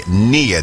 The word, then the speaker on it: Nia: Yeah, we have redubbed it, renamed this day near day, N-I-A, that's how it's spelt, and it simply means purpose.